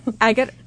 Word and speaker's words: Agutter: I think it's Agutter. – Agutter, that's it. Jenny, you know, Agutter. I wrote it down as a, like, this is how you actually say it, but I still said it wrong. So it's Agater. – I'm terrible. It's Agater.